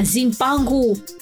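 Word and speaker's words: Cipangu: Ông đã giới thiệu về vùng đất này với độc giả châu Âu thời bấy giờ với tên gọi là Sipangu, phát âm là Zimpangu, với ý nghĩa là vùng đất của vàng.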